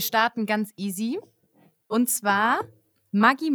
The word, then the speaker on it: Maggi: Maggi, also Julius Maggi, ist Italiener und daher spricht man eigentlich Maggi Maggi aus.